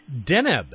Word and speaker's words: Deneb: Deneb. At least that's how you told me it's pronounced. – Deneb.